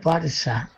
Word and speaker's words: Barça: Y es Barça, pero se escribe Barça, con C de casa. Pero se pronuncia S, esa C en catalán se pronuncia S.